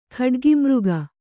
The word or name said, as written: ಖಡ್ಗಿಮೃಗ